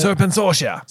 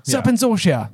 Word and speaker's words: Serpensortia: Is that how you pronounce it? – Serpensortia. – Serpensortia. There we go.